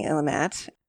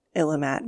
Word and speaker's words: Illimat: I've been saying Illamat. The only place I've heard it talked about is online and in written form. So I think it is because I'm going to make some assumptions about its derivation. And I think that Illamat makes sense as the pronunciation. – Okay. – But it's spelled I-L-L-I-M-A-T.